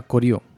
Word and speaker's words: Goryeo: Aunque se escriba Goryeo, se pronuncia Koryo.